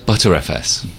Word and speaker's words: Btrfs: ButterFS.